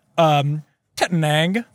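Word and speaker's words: Tettnang: Tettenang, Hallertau, Splatt, and Saz.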